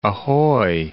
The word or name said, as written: ahoy